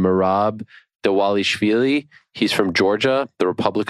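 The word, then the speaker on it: Dvalishvili: Dawalishvili. – Got it. – Yeah. Well, because I was saying Dawalishvili, there's no W in there, but that's how you pronounce his name.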